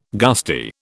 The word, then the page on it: gusty: British English